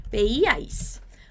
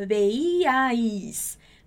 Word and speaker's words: veíais: Y como vosotros y vosotras siempre veíais. Muito cuidado com essa pronúncia. Veíais. Veíais. Aqui a gente vai ter muitas vogais juntas.